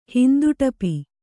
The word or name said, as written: ಹಿಂದುಟಪಿ